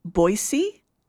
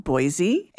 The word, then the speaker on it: Boise: Coming to Boise, they need to learn to pronounce the city properly. It's Boise, not Boise.